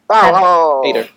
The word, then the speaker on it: Hader: Hader.